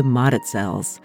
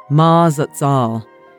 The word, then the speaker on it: Mazatzal: This week, I'm on the Arizona Trail, hiking from the Beeline Highway up into the Mazatzals. The original pronunciation is how it's spelled, Mazatzal. But the locals tell me it sounds more like Mazatzal.